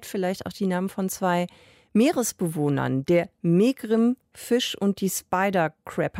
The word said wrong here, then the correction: megrim: Habe ich es jetzt schon mal richtig ausgesprochen? Ah, Martin? – Ja? Ich gehe davon. Migrim. – Migrim. – Migrim. Migrim. – Gut, korrigieren wir das noch.